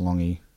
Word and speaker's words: Lange: In 1984, the New Zealand Prime Minister at the time, David Longhi, I'm pretty sure that's how to pronounce that, it's L-A-N-G-E. – L-A-N-G-E? – Yeah, pretty sure it's pronounced Longhi. – Oh, I would have gone Lange. – Yeah, but I've never heard anyone say Lange in my life.